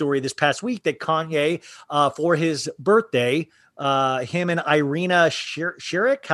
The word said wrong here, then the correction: Shayk: I don't know how to say her name. – Irina Shayk.